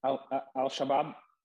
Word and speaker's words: Al-Shabaab: Is that how it's pronounced? Or no? – Al-Shabaab. – Thank you. Cool. Thank you. Al-Shabaab.